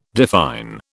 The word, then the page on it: define: British English